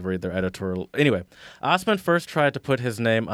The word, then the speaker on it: Ossman: Name David Ossman, spelled Assman. pronounced Osman, and this article is sassy.